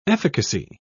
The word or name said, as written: efficacy